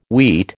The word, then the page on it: wheat: American English